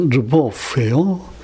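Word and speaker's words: Lwoff: 예, 근데 제일 앞에 얘기를 한걸 보니까 아마 이분이 계속해도 가장 많이 실제로 이제 기여를 한 것 같은데 그다음에 두 번째는 앙드레 르보프예요. 이 스펠링이 좀 이상해요. L하고 그다음에 W O F F. 그러니까 L은 그냥 르, 르 그다음에 보프.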